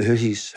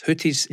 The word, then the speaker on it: Houthis: But anyway, we've been keeping an eye on the, now, how do you pronounce it? Houthis? – The Houthis. – Houthis, yes. In Yemen.